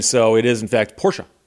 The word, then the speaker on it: Porsche: And that's one of those words that everyone mispronounces. They say Porsche. And, of course, Porsche is a family name from Germany.